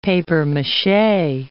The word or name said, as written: papier-mache